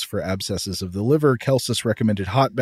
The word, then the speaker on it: Celsus: I think sometimes maybe pronounced Celsus, C-E-L-S-U-S. But I think those Roman C's are supposed to be a hard K sound. So I'm going to say Celsus.